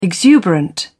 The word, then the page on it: exuberant: British English